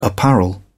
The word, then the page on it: apparel: British English